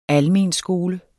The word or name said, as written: almenskole